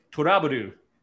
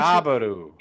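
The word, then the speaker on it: To LOVE-Ru: However, if you were to pronounce it like a Japanese person would, TO is pronounced tall. Love is pronounced rabu, and ru is pronounced ru. Put it all together, and it's turaburu, which is another way of saying trouble. So it's essentially turaburu.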